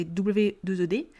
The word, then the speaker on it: we'd: Oui, oui, comme de la weed, pareil. Ça se prononce exactement de la même façon. Ça ne va pas s'écrire pareil, attention. Weed de l'herbe, c'est w e d Là, weed rather, ça va, s'écrire W-E apostrophe D pour pouvoir former mon weed rather.